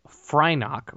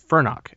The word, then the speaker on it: Fyrnock: Before finally setting on Frynok or Fyrnock, actually, I'm sorry, I think we're saying it wrong. It's Fyrnock, F Y R uh, N O C K.